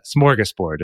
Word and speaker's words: smorgasbord: It's borg. Board. – Yeah, I've seen that one too online, but it's board. So B O R D is the board. And then smorgas is spelled with some some fancy little symbols over the letters that we don't normally have in American English. But uh, smorgasbord is how it's said.